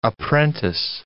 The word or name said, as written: apprentice